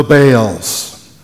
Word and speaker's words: Baal: Baals. In Hebrew, it is pronounced Baal. I'll stick with Baals. That's a little easier to say.